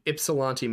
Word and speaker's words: Ypsilanti: Ypsilanti? Ypsilanti, I assume that's Michigan? – Michigan. Ypsilanti, Michigan. – Oh, I did get it right.